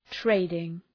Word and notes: trading: ‘treıdıŋ

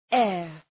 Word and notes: heir: eər

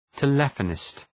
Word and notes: telephonist: tə’lefənıst

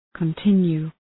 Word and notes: continue: kən’tınju:

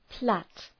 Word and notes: plait: pleıt

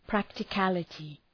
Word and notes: practicality: ,præktı’kælətı

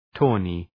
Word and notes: tawny: ‘tɔ:nı

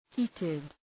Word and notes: heated: ‘hi:tıd

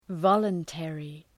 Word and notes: voluntary: ‘vɒlən,terı

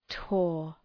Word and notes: tore: tɔ:r

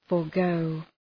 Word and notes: forgo: {fɔ:r’gəʋ}

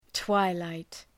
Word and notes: twilight: ‘twaı,laıt

